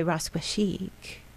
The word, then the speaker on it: Rascua Chic: my vision and now Augusto's vision with Runway Rascua Chic. You have to say it, Rascua Chic. – You have to roll the R's.